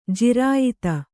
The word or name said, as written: ಜಿರಾಯತ